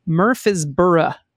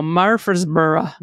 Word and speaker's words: Murfreesboro: I thought I would add the local native way of pronouncing, assuming not many locals listen to the pod. Natives say, Jesus, Murfreesboro. – Murfreesboro. – Said slurred together in a drunken southern drawl, Murfreesboro.